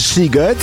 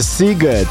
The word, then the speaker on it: Sziget: Wcześniej Jazzy i Can't Deny It, bo to jest tak chyba po węgiersku, że szy czyta się jako sy, a sy jako szy, prawda? Bo to jest bardzo ważna rzecz, bo pisze się Sziget, a wymawia się Sziget.